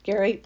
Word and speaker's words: Gerry: Gary, G-E-R-R-Y, but pronounced Gary, my mate, has done that.